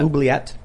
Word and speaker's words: Oubliette: Is that how you pronounce it? Was it? – Yeah, Oubliette. – Oubliette.